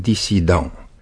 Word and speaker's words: disident: Asta e, cred că explicația vine din faptul că în limba franceză, acolo de unde l-am primit, se scrie cu doi S, deci pronunțăm dissident.